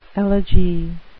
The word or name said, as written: elegy